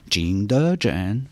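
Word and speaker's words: Qingdezhen (景德镇): Alors c'est la céramique qui vous a fait découvrir Ting de Chen. – Oui. – la capitale mondiale de la porcelaine. Et comment on prononce Qingdezhen? – Qingdezhen. – Ah ouais, c'est pas pareil.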